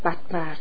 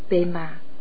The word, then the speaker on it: Padma: Câu chú tôi khuyên các học trò tôi đọc là Om Ahom Vajra Guru Padma Siddhiyo. Người Tây Tạng nói Om Ahom Bensa Guru Pema Siddhiyo là thần chú của Padma Samphawa, của tất cả chư Phật, các bậc thầy và những bậc đã chứng ngộ.